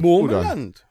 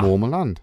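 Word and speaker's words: Moormerland: Ich hoffe, ich habe das jetzt richtig ausgesprochen. – Im Mormerland. – Ja. Mormerland.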